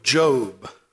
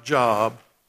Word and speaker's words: Job: And if you want to turn to the book of Job. I know it looks like Job, but it's pronounced Job.